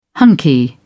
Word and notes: hunky: British English

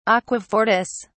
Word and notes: aquafortis: British English

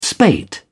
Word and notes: spate: British English

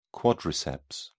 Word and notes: quadriceps: British English

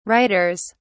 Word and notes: writers: British English

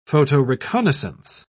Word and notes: photoreconnaissance: British English

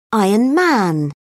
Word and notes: iron man: British English

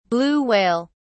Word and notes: blue whale: British English

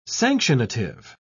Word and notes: sanctionative: British English

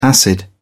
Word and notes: acid: British English

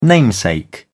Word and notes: namesake: British English

